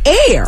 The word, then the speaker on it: Aire: It is Air. It's spelled A-I-R-E.